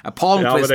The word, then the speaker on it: Palmqvist: De har ju lastat in Weigel, Olle Liss, de har tagit in Vessel också och vad säger man? Palmqvist, va? – Palmqvist. Du får kolla på Lars Lindbergs Twitter där. Jag reserverar mig för att uttala namn känner jag nu. Det biter en i rumpan direkt. Palmqvist, ja, det måste det vara.